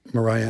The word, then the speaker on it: Mariah: And they said Mariah, and I said rhymes with pariah, which is a terrible thing to say about anyone.